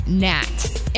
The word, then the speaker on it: gnat: That was the word gnat. It has a silent G, in case you're wondering where I went wrong.